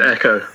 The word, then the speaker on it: Ekow: Mr Ekow.